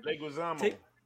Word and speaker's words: Leguizamo: Actor John Leguizamo, whatever. – Leguizamo.